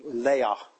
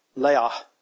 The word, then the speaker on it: Leah: One was the elder, Leah. That's not how it really pronounced, by the way. She pronounced Leah. Leah.